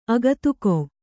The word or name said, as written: ಅಗತಿಕ